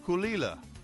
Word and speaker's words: Caol Ila: It is spelled C-A-O-L-I-L-A, but it's pronounced Kulila.